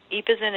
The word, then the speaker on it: Ypozane: Epazin. It's spelled Y-P-O-Z-A-N-E, Epazin.